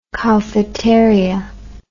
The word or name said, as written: cowfeteria